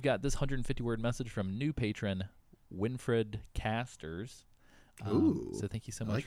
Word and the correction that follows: Winfried: Hopefully I'm pronouncing that correctly. It's Winfried Casters.